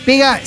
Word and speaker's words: Pegha: Pega se escribe P-E-G-H-A.